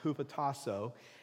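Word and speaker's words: hupotasso (ὑποτάσσω): The Greek word is pronounced hupotasso.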